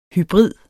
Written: hybrid

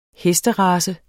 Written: hesterace

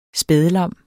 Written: spædlam

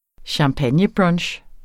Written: champagnebrunch

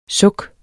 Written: Sukkene

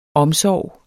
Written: omsorg

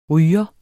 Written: RYGERE

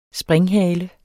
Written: springhale

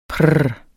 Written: prr

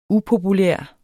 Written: upopulær

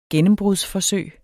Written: gennembrudsforsøg

